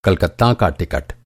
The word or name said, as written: टिकट